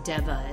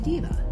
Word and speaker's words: Deva: but it's Deva is one of them, and it's D-E-V-A, which if you mispronounce it, it'd be Diva.